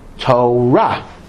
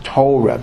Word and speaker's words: Torah: and Torah also is Torah, not Torah, because in Hebrew most words are accented in the last syllable, not the first syllable.